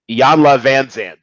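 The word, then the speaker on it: Iyanla Vanzant: Although the Regina Hall character, she's kind of based on like a mini Oprah in training, like a souped up why can I never say her name? Iyanla Van Zandt. There we go.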